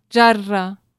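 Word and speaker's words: jarra (جرة): El árabe lo pronuncia más como la J catalana y obviamente estamos hablando castellano, lo pronunciamos como jarra, pero lo pronunciaste más al estilo catalán.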